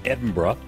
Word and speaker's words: Edinburgh: We're sitting in a meeting room here at Charlotte Chapel in Edinburgh.